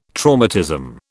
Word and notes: traumatism: British English

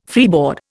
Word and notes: freeboard: Indian

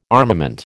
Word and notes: armament: American English

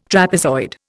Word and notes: trapezoid: Indian

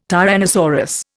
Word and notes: tyrannosaurus: Indian

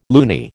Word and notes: loony: American English

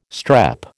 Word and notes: strep: American English

